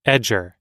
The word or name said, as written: edger